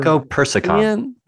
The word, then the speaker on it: Lycopersicon: So in the 18th century, the tomato species was named Lycopersion. How do you say that? Lycoper- Lycopersicon. Persicon. Okay.